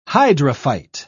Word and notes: hydrophyte: British English

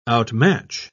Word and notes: outmatch: British English

